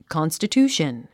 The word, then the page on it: constitution: kὰnstətjúːʃən; カンスタチューション